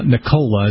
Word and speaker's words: Nicola: Nicola, is that the correct pronunciation? – Yeah, Nicola Bulgari, who many people probably recognize or have seen his automobiles.